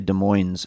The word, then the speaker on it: Des Moines: Now, I know you heard me say Des Moines, and you probably, if you don't live in Washington State, you're thinking, why are you mispronouncing Des Moines? It's because that's how we say it in Washington, for whatever reason.